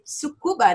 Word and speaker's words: Tsukuba: A Marina tá falando lá do Japão, da cidade de Tsukuba, né? É isso? Falei certo? – Sim, e ela embarcou para o Japão, assim, poucos dias depois dessa gravação.